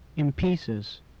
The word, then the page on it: in pieces: English